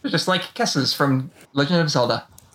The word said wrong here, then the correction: Keese: Keese. – Keese, is that how you pronounce it? Keese, Kessa, I forget. Oh, well. – At least in English, I usually think it's Keese.